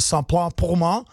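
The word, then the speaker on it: Ça plane pour moi: tak dokážu tenhle název vyslovit ve francouzštině tak, jak se správně vyslovit má, tedy skladba od Plastika Bertranda, jmenuje se Saint-Plain Poma.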